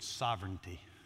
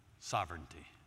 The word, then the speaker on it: sovereignty: It's the word sovereignty. Sovereignty. Write it down, learn to spell it. Sovereignty. Sovereign. R-E-I-G-N. Reign. Sovereign.